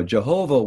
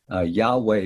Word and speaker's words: Yahweh: So the, you know, Jehovah was the older guess about the pronunciation. Yahweh is the newer consensus.